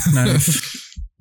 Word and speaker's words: knife: Knife. – You said knife? That's a silent K, dude.